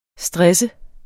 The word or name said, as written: STRESSES